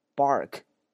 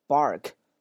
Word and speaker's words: bark: 这个单词里面请大家注意中间 ar 这个单词的发音，只要以 ar 这两个字母出现，它一般都读这个 r bark，或者是车叫 car。在美式发音里面，这个卷舌还是比较突出的，bark car， OK 啊，bark car，这第一个单词狗叫，狗的那种啊狂吠都叫这个 bark。